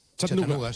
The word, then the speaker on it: Chattanooga: Chattanooga.